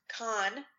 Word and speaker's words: Cannes: And then I'm pronouncing this one right. I know because I asked. Cannes.